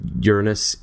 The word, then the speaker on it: Uranus: We had Uranus and Neptune for the first time.